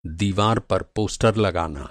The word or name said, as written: पोस्टर